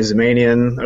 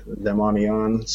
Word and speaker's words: Zamanian: That's actually closer to the original, like, Iranian pronunciation, which is, I guess, different than here we say Zamanian. Over there, it's like Zemanian.